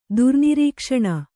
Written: ದುರ್ನಿರೀಕ್ಷಣ

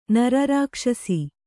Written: ನರರಾಕ್ಷಸಿ

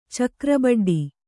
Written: ಚಕ್ರಬಡ್ಡಿ

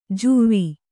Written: ಜುವ್ವಿ

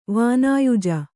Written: ವಾನಾಯುಜ

